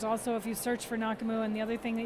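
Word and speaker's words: Nake'muu: It's N-A-K-E apostrophe M-U-U, and actually pronounced Nakamu.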